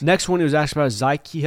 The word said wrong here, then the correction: Zakai: Helton, which we have a new name pronunciation, so it's a shame Brooks isn't Zakai. – It is pronounced Zakai. – I have alarmed him about this. I have told Brooks it is Zakai Helton.